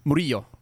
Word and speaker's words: Murillo: Your last name is pronounced Murillo. – Murillo. – Murillo. – Yeah, it's a Spanish name.